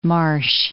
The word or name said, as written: Marche